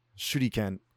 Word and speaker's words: Shuriken: Shuriken.